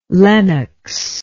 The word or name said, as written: Lenox